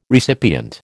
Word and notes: recipient: American English

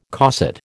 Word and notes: cosset: American English

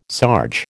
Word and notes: sarge: American English

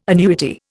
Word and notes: annuity: Indian

